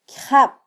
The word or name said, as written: kyjab